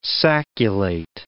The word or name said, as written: sacculate